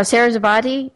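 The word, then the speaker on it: Saraswati: How do you say that? Do you know who I'm talking about? – Sarasvati or something like that. That's what you said. – Yeah, Sarasvati.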